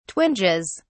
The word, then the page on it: twinges: British English